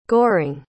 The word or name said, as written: goring